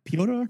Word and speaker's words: Piotr: However, I really like Peter Kowalski. Is that really how you say that name? – Yeah, Peter. It's just a Russian spelling.